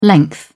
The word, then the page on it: length: British English